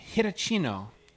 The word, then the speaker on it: Hitachino: So I've never heard of this because I don't live in Japan, but Japanese brewery, hopefully I'm not butchering the name, Kiuchi, K-I-U-C-H-I, Kiuchi Brewery. And what we're about to try is the Hitachino, H-I-T-A, Chino, Hitachino Nest Espresso Stout.